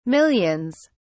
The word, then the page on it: millions: British English